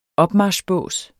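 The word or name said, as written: OPMARCHBÅSE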